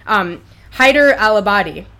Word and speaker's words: Haider al-Abadi: We have one, which is that Nouri al-Maliki has resigned and has endorsed Haider al-Abadi.